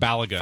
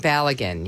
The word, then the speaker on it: Balogun: Did I say it right? – Fowler and Balogun. – Fowler and Balogun, yeah.